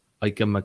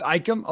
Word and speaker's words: Ikem: Most people say Icky because I think it's pretty hard to say Ikem Ekwonu, but. – Oh, it's Ikem.